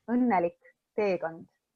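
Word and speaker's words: Õnnelik teekond: So it's pronounced Unnelik Teekond.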